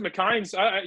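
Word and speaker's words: McInnes: It's pronounced McKines.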